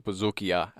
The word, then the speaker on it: bouzoukia: And I mentioned the bouzouki, which I mispronounced. It's a bouzoukia, actually.